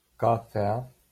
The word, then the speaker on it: cafea: Cafea. Doctor. Rucsac. – В слове кафе еа является дифтонгом. То есть мы не произносим кафе-а, произносим кафе. Еа читаем слитно.